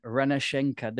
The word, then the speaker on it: Renascença: She's from the Portuguese publication Renascença. Did I say that right? – Let's say you did. – Okay. Excellent. – That's really passive aggressive from you. – No. I'm kidding. It's close enough.